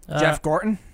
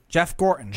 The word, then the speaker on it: Jeff Gorton: Jeff Gorton. – I was calling Jeff Gordon. – Jeff Gorton. – Jeff Gorton. – Yeah. – Jeff Gorton.